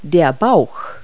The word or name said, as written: belly